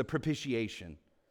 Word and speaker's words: propitiation: And the second word he uses is this word hilasmos for atonement, which is in verse 2 that he's the propitiation. Now, that's a big word, right? How many syllables is that? Propitiation. Five.